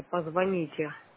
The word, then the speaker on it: позвоните: Спасибо, но запомните, позвоните. Ударение на другой слог.